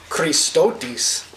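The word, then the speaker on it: Christotis (χρηστότης): Gentleness is Christotis in the Greek. I listened to a Greek person say that, so I could say it right. Christotis.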